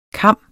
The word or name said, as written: kam